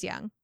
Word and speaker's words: Jung: I was saying it right. It is young. – So there's no je. It's just young.